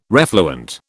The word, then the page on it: refluent: British English